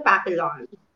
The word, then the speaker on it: papillon: Yung spelling niya kasi is papillon. Pero ang pronunciation niya, it's really quite different. It's the papillon.